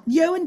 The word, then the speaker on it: Ewan: And then, you know, Ewan Stewart's name is spelt E-W-A-N, mm-hmm. which phonetically is E-1, right?